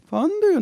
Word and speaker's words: Fondue: Fondue und Raclette. – Mit der Betonung vorne. Fondue, Raclette-Käse.